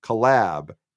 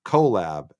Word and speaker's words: collab: Apparently it is pronounced collab, emphasis on the second syllable, not collab, as I had said.